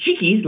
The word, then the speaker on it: Chiquis: Am I saying that right? – Yeah. So it's Chiquis, La Chiquis.